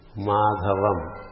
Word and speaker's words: Madhavam: If we say, for example, Madhavam. Ma-dha-vam. Madhavam. Ma-m is not taken as a syllable. Madhava-m. Madhava is 3 syllables.